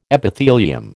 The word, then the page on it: Epithelium: American English